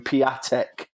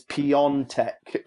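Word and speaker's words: Piątek: And I used to call him Piatek. And they told me his name's Piontek, apparently.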